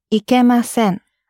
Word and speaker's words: ikemasen: And in the foundation course, we were quite strict on how you pronounce the word ikimasen, to not go. And the reason why we were particular about your pronunciation, because sometimes ikimasen can sound quite like ikemasen, and they mean completely different things.